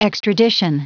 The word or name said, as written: extradition